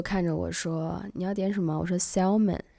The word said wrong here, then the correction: salmon: 然后他说 salmon L 不发音，那是 salmon。